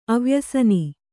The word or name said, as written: ಅವ್ಯಸನಿ